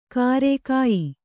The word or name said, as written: ಕಾರೆ